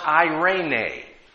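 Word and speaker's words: Irene (εἰρήνη): In the New Testament, there's a Greek word that is used, and it's pronounced Irene.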